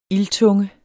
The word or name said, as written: ildtunge